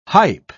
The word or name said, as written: hype